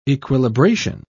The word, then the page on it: equilibration: British English